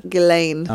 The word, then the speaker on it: Ghislaine: Ghislaine is what I've heard. Ghislaine.